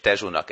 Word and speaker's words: Tejo: A Lisszabont átszelő folyó, a Tejo, meghatározza a település arculatát. Ezt úgy írják egyébként, hogy Tejo, és Tejo-nak ejtik, Portót meg Portunak, tehát a szóvégi ó helyett út kell ejteni,